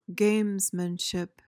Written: gamesmanship

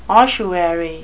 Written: ossuary

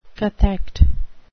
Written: cathect

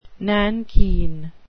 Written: nankeen

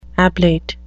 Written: ablate

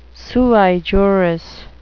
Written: sui juris

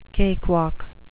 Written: cakewalk

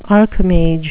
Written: archimage

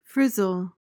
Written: frizzle

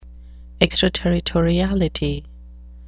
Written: extraterritoriality

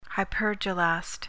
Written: hypergelast

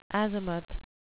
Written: azimuth

